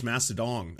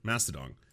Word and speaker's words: Mastodon: It's pronounced Mastodon. Oh, sorry, Mastodon.